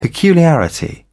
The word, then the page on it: peculiarity: British English